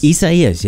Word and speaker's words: Isaias: Isaias, yes, that's correct. Isaias. That's how you say it.